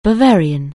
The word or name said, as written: Bavarian